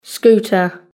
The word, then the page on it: scooter: British English